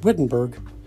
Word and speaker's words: Wittenberg: It looks like Wittenberg, but the Germans pronounce it Wittenberg.